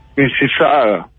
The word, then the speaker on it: Mississauga: Sí, claro, sí, bien, ¿se pronuncia Mississauga? – Mississauga.